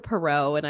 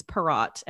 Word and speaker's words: Perrot: Oh, which I want to quickly, like, not correct, but like, I think I switched from saying her last name from Perrot to Perot, and it's Perrot. And I think I just, the French took over, like, the French side of wanting to not pronounce the T.